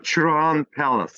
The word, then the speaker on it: Çırağan Palace: So it looks like phonetically Çırağan Palace. Kempinski? – Yes. That's right. We call it Çırağan Palace. – Çırağan.